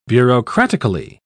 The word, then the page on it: bureaucratically: British English